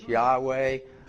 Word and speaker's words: Yahweh: Scholars are divided on how we should pronounce that. Hebrew became a dead language, so we lost the exact pronunciation of YHWH. Most scholars think it's Yahweh. A lot of rabbis think it's what is it? I forgot. Yaba. You know, there's about three different ways that it's pronounced. Most say Yahweh.